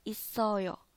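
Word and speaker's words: isoyo (있어요): 后面这个呢读作一，然后 s o so i o yo，所以连起来是 isoyo isoyo 啊，isoyo 呢在韩语当中它就是在的意思，在。所以呢整个句子连起来就叫做 menu yogi isoyo。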